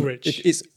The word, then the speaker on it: Woolrych: So many people say it wrong. It's Woolrych. It's wool. If you think about it, it's wool as in sheep, rich as in money, but spelt with a Y instead of an I.